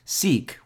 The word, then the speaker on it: CEEK: Seek, which by the way is spelled C-E-E-K, has an ICO that's coming up in a couple of weeks, and Gazecoin recently completed their ICO about a month ago.